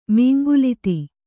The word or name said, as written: ಮೀಂಗುಲಿತಿ